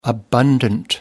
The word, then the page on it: abundant: American English